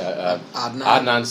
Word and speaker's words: Adnan: Adnan Syed.